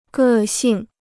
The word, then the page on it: 个性: gè xìng